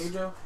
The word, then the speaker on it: Keijo: Is a new show, new anime called Keijo. Is that how you say it? – Yes. – Keijo.